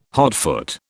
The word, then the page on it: hotfoot: British English